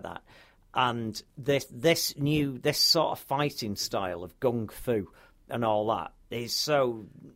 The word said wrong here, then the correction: gun-fu: Gung-fu. Gun-fu. Not gung-fu. I keep saying it wrong.